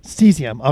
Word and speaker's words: Cesium: I saw today that, how do you say the name of the company? Is it Cesium? – Cesium.